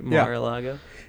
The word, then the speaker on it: Mar-a-Lago: Am I saying it right? Mar-a-Lago. Yeah.